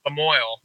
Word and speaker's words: Lamoille: How do you pronounce it? – Lamoille, Illinois. Oh, Jesus. Yeah. – I tried to go French, and it's as damn as American as Lamoille.